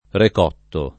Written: recotto

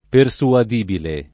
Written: persuadibile

